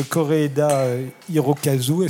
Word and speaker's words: Kore-eda Hirokazu: Vous êtes à l'affiche de La Vérité, un film de Kore-eda Hirokazu. Est-ce que je l'ai bien prononcé? – Absolument.